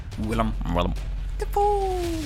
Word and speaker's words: Willem Dafoe: Somebody said a joke that if you say his name with a, it sounds like a frog, and then sounds like a parrot at the end. – Willem Dafoe.